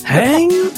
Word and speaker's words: hein: Häng! Ja. H-E-I-N!